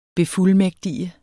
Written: befuldmægtige

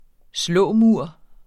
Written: slåmur

